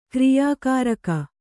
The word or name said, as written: ಕ್ರಿಯಾಕಾರಕ